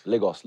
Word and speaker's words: Lagos: So, how do you say it? It's not, it's Lagos? – Lagos.